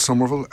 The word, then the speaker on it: Somerville: It's just Fergal Somerville, and the Somerville is spelled S-O-M-E-R-V-I-L-L-E,